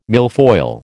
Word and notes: milfoil: American English

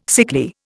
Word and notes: sickly: Indian

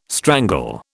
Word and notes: strangle: British English

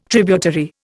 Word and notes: tributary: Indian

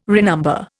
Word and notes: renumber: Indian

